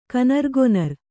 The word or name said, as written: ಕನರ್ಗೊನರ್